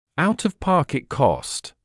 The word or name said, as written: out-of-pocket cost